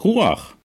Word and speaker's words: Ruach: Das erste jedenfalls ist Ruach.